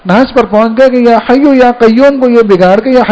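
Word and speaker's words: یا حی یا قیوم: تو انہوں نے طریقے سے اصلاح کی, کہا کہ میرے عزیز یہ جو وظیفہ آپ پڑھ رہے ہو نا, تو یا حیو یا قیوم ہے.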